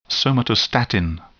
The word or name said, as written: somatostatin